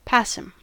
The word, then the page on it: passim: American English